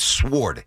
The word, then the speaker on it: sword: It's not sword. S-O-R-D. It's sword. – I know how you spell it. – I know you do, but you got to say it the right way. It's sword.